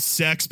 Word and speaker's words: CeX: Balan Wonderworld is a game that I bought secondhand from a store that I believe is called Sex, but that cannot be right. That is right.